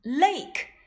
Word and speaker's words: lake: 原来呀，它们都不发音。 Lake l a k e lake bike b i k e bike rose r o s e rose Kate k a t e Kate 这个 e 呀，我们也叫做哑音 e。